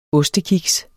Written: ostekiks

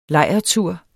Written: lejrtur

